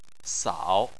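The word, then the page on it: sao: tone 3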